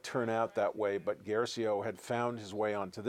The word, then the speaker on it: Guercio: I think Guercio is how it's pronounced, who did um, Electric Glide in Blue, which is a movie that is available today.